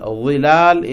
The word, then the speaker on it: ظلال: ظلال is shadows. ظل. So always pronounce with the letter ظ, ط, ظ. Both are emphatic letters, heavy letters in Arabic.